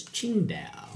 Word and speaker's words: Tsingtao: And if we pronounce it correctly, it's Tsingtao.